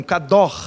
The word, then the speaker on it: kadosh: Santo na Bíblia, kadosh é o, é o conceito, a pronúncia seria algo parecido com kador, né? Kador, que é o gutural ali, né? Então assim, a gente fala no popular kadosh, né?